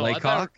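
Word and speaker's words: Le Coq: Oh, Lecoq. I thought you were going to pronounce it as like LaCroix. I was like, that doesn't look right.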